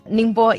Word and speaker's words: Ningbo: Our iron ore goes to Ningbo. Have I said that right? Ningbo, yes.